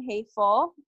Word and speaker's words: Hefel: So Instagram, my handle is Wesley Ann Hefel. My last name is spelled H-E-F-E-L.